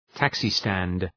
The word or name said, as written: taxi stand